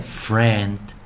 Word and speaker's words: friend: 同学们注意到一件事情哦，这是念 friend。 friend, friend，也就是前面的 i 没有发音，e n d 念 and friend, friend，也就是我们讲的朋友啊。